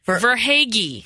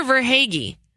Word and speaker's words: Verhaeghe: And we apologize if we're butchering it, but we searched the social media webs, interwebs, for how to pronounce Carter Vaghi. Did I do it right? – No, you didn't. – Verhaeghe. Carter Verhaeghe. We'll